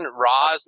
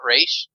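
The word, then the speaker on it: Ra's: Someone call Bailey and let's get a ruling, but is the official TBU pronouncing Roz, not Raish? – Well, there's people who say Raish. – I don't, I do the Raish. – Some of the podcasts, I say Roz.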